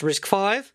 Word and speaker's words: RISC-V: He said, this falls in the picky category, but RISC-V is pronounced RISC-V. This is because it is the fifth reduced instruction set computer developed at Berkeley and Stanford universities, I assume, in the U.S.